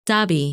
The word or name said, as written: Dabih